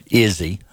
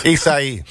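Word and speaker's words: Isaias: 8 o'clock tonight, and this was scheduled this weekend, but they've moved everything up because of Hurricane Izzy. – Isai.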